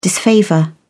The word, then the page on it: disfavour: British English